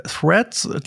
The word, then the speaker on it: Threads: It has two consonant blends, a thr and a z, with a non phonetic e in the middle. Threads. Threads. Threads. – Ja, also das Thema ist äh, Threads, die äh, Twitter-Alternative, Twitter-Klon von Meta.